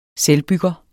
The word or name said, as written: selvbygger